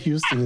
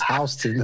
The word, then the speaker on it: Houston: I learned that when I went to, like, it's not Houston, it's Houston. I was like, okay. – I came, I came so, to New York from Texas, so I was calling it Houston.